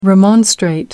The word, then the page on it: remonstrate: American English